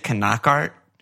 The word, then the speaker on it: Knockaert: And it's it's the the announcers are right. You know how they always say Knockaert? – Yeah.